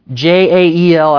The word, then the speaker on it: Jael: The same word is translated pounded in Judges 5, which describes jail, Jael, I always forget how to say this dude's name, that pounded a tent peg through the head of Sisera.